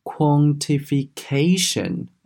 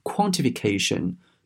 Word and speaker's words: quantification: 要注意它的名词形式呢，重音在后面啊，读作是。 quantification 啊，你不要把它读成是 quantification，这样子呢是不对的啊。正确的读法应该是 quantification，quantification。